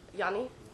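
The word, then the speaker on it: Jani: Johnny. – Johnny. Okay. – Johnny. – Yanni? – Yeah, all right. – Like, you pronounce it with Y.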